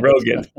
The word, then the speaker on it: Rogan: I don't know how you pronounce that. – Rogan.